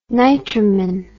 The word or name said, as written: nitromin